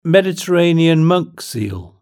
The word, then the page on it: Mediterranean monk seal: British English